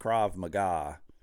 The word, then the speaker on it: Krav Maga: Now, I know it's pronounced Krav Maga, but it's Maga, M-A-G-A.